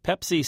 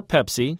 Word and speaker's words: Pepsi: It's a new gambit to sell more Pepsi to Spanish speakers. Marketplace's Mitchell Hartman reports. – In the ad within an ad, an American director keeps correcting footballer Fernando Torres's pronunciation of Pepsi.